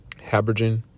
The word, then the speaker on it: habergeon: or harbient. How do you say that, y'all? Habergeon, with a band around about the hole that it should not rend.